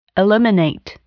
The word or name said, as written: eliminate